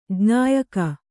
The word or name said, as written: ಜ್ಞಾಯಕ